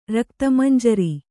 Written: ರಕ್ತಮಂಜರಿ

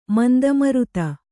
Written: ಮಂದಮರುತ

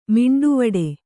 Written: ಮಿಂಡುವಡೆ

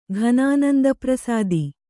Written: ಘನಾನಂದಪ್ರಸಾದಿ

